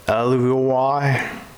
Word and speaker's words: alveoli: When the pneumonia occurs, the thinner layers of alveolar when pneumonia occurs, the thin layer of the alveoli, that's how you say it, alveolar cells, is damaged by the virus.